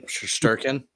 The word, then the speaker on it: Shesterkin: Shesterkin? – Yeah, close enough.